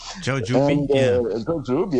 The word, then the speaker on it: Jaojoby: and the writing is J-A-O-J-O-B-Y, and the pronunciation, I'm not sure, is Zhoujub. – Zhoujub, yes.